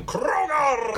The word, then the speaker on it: Krogar: You gotta roll your R's like this. The creature from Krogar!